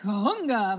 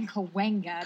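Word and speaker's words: Cahuenga: And then Sam, he's like, Kahunga. I'm like, Kawenga. Kawenga.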